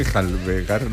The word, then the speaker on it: enjalbegar: Para que volviera a enjabelgar, ¿cómo se dice? Enjalbegar.